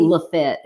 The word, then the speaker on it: Lafayette: Lafitte. It's not Lafayette. – Don't you dare call it Lafayette. – Oh, it's Lafitte. – It'll piss her off so bad. Is it spelled like Lafayette? It sure is.